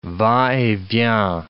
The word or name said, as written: va et vient